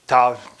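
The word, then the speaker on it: Ptah: It's A-M-U-N. And that all gods are three, Amun, Re, and Ta. Ta spelled with a P, P-T-A-H.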